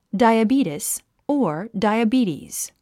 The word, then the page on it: diabetes: dàiəbíːtis; ダイアビーティス